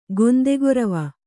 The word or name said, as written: ಗೊಂದೆಗೊರವ